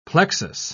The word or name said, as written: plexus